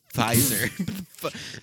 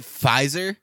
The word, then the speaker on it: Pfizer: Pfizer. Why? Okay. Now that we're even on this, PF, that's not even, you can't even pronounce that. Pfizer. Is it Pfizer? Because then it just should be an F.